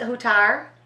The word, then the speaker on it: Hutar: Huttar? – Yes.